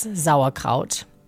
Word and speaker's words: Sauerkraut: Really looking back, something that has been big in my family, being German, is Sauerkraut, or as you might say, sauerkraut. Um, but the S in German is pronounced with a Z or Z. So it's Sauerkraut.